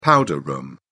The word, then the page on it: powder room: British English